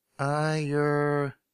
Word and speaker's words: Ayurvedic: This is an old Ayurvedic? How do you say that? A-Y-U-R-V-E-D-I-C. Ayurvedic? Oh, here. How do you pronounce it? Ayurveda.